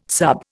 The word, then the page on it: sub: Indian